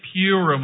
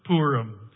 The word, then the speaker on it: Purim: I know we tend to say Purim like there's a Y after the P, Purim. That wouldn't be a very Hebrew way of pronouncing that word, Purim.